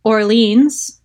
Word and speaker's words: Orleans: Over to Orleans. Sound familiar? – Love it. – So, and this is the pronunciation, even though New Orleans or New Orleans can be said in other ways.